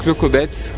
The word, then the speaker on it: Clocobets: le Clocobets, oui. On prononce comme ça, est-ce que tu le parles couramment ? – Je parle couramment, oui.